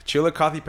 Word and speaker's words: Chillicothe: Chillicothe Paints.